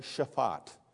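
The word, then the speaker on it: shaphat: The word there is shafat. Very simple, two syllables, so I'll dare spell it for you. S-H-A-P-H-A-T. S-H-A-P-H-A-T.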